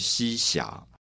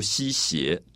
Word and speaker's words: 西斜: 我读霞。是因为古代发音的押韵啊，因为前面是可怜春半不还家啊，是发花韵，所以这边要发音要读西霞，所以也是押韵的关系。如果你读西斜啊，斜下去的斜，它就不押韵了。